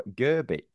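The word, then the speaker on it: Grbić: We obviously signed the goalkeeper, and he apparently is pronounced Ivo Grbic.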